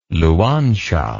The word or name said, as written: Luanshya